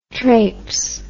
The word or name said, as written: trapse